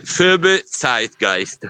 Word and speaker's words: Phoebe Zeitgeist: La pronuncia corretta è? – Phoebe Zeitgeist. – Perfetto, così l'abbiamo detto giusto.